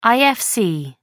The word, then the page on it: ifc: American English